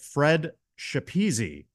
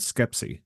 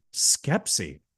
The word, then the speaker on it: Schepisi: I looked at the director's name as I would personally in my head was pronouncing it Fred Schepisi, but I think you pronounced it differently. Do you know offhand? Did you hear in an interview by any chance how it is pronounced? – I looked it up and phonetically it said Skepsi.